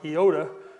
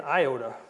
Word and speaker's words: iota: It's actually pronounced iota. I would have always grown up saying iota.